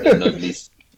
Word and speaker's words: Noblesse: Anyone else watching Noblesse? – Yeah, I'm watching Noblesse as well. – This guy's watching. – It's pronounced Nobles. – It's pronounced Nobles. All right, Nobles.